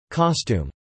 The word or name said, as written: costume